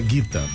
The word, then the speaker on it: Gita: A gente fala Gita, mas não é Gita não, viu? É Guita. Pronúncia correta é Guita.